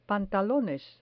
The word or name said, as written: pantalones